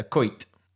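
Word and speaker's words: koyt: And question number 10, a koit. – K-O-Y-T. K-O-Y-T. – How would I pronounce that? – Koit. – Koit? – Yeah, sounds correct.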